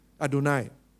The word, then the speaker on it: Adonai: So whenever you see this word here, they pronounce it as simply Adonai.